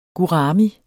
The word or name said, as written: gurami